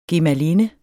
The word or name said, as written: gemalinde